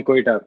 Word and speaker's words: Quetta: you're a guy from Quetta. – Yeah, I'm from Quetta, bilkul.